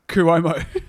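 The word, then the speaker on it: Cuomo: I have told you how to pronounce it at least six times, so give us your best get bear on how it is pronounced. – Kuomo. – Yeah, Kuomo, you got it, brothers.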